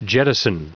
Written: jettison

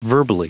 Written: verbally

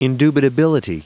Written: indubitability

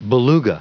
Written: beluga

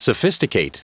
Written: sophisticate

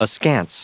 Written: askance

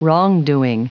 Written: wrongdoing

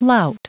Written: lout

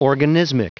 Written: organismic

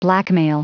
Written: blackmail

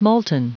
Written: molten

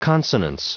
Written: consonance

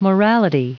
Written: morality

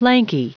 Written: lanky

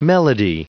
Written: melody

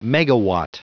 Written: megawatt

